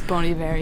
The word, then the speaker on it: Bon Iver: 3, 4, let's go with I'm going to say it the wrong way. – Go for it. – Bon Iver. – Fail it. Bon Iver. – But it's Bon Iver, yeah. – Did you know how to pronounce it properly? – I know how to pronounce it. I just kind of feel like prissy if I say Bon Iver. So I just say Bon Iver and then just let people correct me.